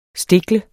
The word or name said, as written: Stiklet